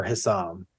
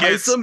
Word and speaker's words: Hyson: I think it's Hyson because that's what he introduced himself as. – Okay. Yeah, it's Hyson. And I actually wrote on my notes how to pronounce it, and I wrote hi and then saw like S-A-A-M so that I'd remember that.